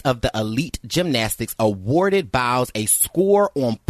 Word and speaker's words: Biles: Biles? – Yes.